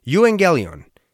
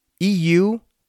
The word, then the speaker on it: euangelion: It might be pronounced a little bit differently nowadays, but this is how it was pronounced previously and how it was written. So, euangelion, euangelion, which means good news.